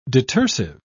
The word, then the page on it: detersive: British English